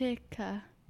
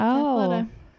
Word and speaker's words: Athleta: Athletica. No. – Nope. It's Athleta.